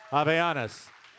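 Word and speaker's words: Havaianas: Avayanas. The H is silent.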